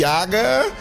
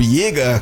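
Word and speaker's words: Jäger: Vamos agora de Yaga, né? O Yaga, que seria o caçador, com o Zugigaudi auf der Valle Alm. Falei certo agora, Lizzy Light? – Ja, tá certo.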